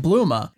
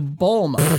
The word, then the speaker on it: Bulma: So I would like to, and I thought about it and I'm not sure how to go about doing it other than maybe just start saying Bluma instead of Bulma.